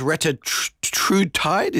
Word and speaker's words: Retatrutide: What is Retatrutide? Is that how we say it? Forgive me. – Yes, yeah, I believe that is how you say it, so well done.